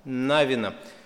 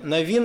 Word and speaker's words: Навин: Как правильно, Навин или Навин? С еврейскими именами всегда сложность, где ударение ставить. Для русских, наверное, привычно Навин, да? Иисус Навин. Правильнее как? Без разницы – Слава Богу, сегодня многие моменты в русском языке откорректировали и, и так правильно, и так правильно.